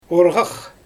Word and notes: orGax: Xyzyl

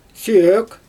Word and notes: søøk: Xyzyl

